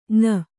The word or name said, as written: ಞ